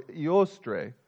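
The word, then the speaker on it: Eostre: The term Easter goes back many centuries, originally connected to a goddess, a pagan goddess of the dawn, pronounced Ostara or Iostre.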